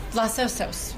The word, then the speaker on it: Los Osos: How do you pronounce it? O-S-O-S Vineyard. – Los Osos, which means the bears in Spanish.